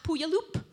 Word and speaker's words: Puyallup: And the Washington State Fair is in Puyallup, not Puyallup. Now, by far the best thing you can do to keep connected to the city is hang out with other humans.